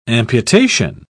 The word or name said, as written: amputation